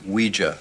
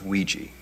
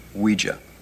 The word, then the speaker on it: Ouija: It's pronounced Ouija, not Ouija. It comes from the French and German words for yes, oui and ja, Ouija.